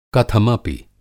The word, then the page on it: कथमपि: Hindi